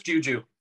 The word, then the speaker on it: JJUUJJUU: Just JJUUJJUU.